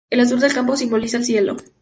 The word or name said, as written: cielo